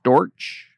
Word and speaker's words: Storch: Storch. Storch, am I pronouncing that right? Storch? – Yep, you got it.